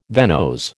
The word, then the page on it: venose: American English